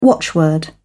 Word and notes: watchword: British English

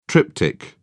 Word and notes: triptych: British English

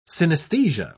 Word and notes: synesthesia: British English

